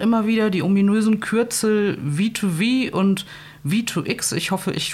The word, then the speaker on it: V2V: Ja, äh, V2V ist äh, klassisch äh, englisch richtig ausgesprochen.